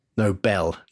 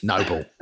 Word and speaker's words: Nobel: Nobel. – Noble. If you're from London like I am, it's Noble.